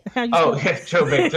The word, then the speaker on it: Joe Bates: Joe Bates. A very hard name to spell. J-O-E. – Yes. – B-A-T.